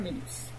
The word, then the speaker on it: Milius: Milius, así es que se pronuncia, ¿verdad? Milius.